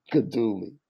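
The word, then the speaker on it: Guidugli: Today, Gino Jagluga. – Gadula. No, Gadula. Gino Gadula. Man. – Gino Gadula. Okay. – Gino Gadula.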